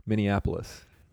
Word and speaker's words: Minneapolis: Is there an extra N in there somewhere? Minneapolis? – You're thinking of how it's spelled. It's Minneapolis.